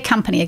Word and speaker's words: company: You said, say company again. – Company. So it's going to rhyme with cup.